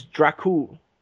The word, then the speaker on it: Dracul: I know what you're thinking, but the Dragon in, like, whatever language they spoke, maybe it was Romanian, is Dracul. – Yeah. – That's how you say it.